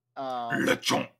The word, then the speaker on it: Lechonk: Lechonk. You gotta say it correctly. Lechonk.